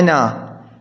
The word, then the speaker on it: أنا: على كل، فنخلط عندما أقول لك أنا فيها ألف أو لا، تقول نعم فيها ألف، تكتبها أنا، هذه الألف تكتب لكن لا تلفظ، لا تقول أنا خطأ، تقول أنا قائم، أنا جالس، كما في البيت الشعري المعروف أنا مذنب، أنا مخطئ، أنا عاصي، هو غافر، هو ساتر، هو كافي. فأنا مذنب، أنا مخطئ، لا تقول أنا مذنب.